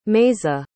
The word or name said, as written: meaza